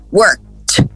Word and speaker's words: worked: Então é como se fosse um K e um T no final. Worked, worked, worked. Observe, a palavra tinha uma sílaba e continuou com uma sílaba. Então ela não pode ganhar uma sílaba extra, porque senão você está pronunciando E. Então, worked.